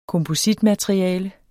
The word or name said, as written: kompositmateriale